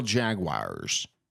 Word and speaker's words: Jaguars: How do you say Floridians say it? – How do they say Jaguars? – Yeah. – They say Jaguar. – Do they? – Yeah. – Jaguar. – Like J-A-G-W-I-R-E.